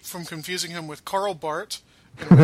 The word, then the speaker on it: Barth: Well, one way that we prevent the confusion is by pronouncing his name rightly. So, yes, Barth. I don't know how I can help you with Roland, except that there's no S.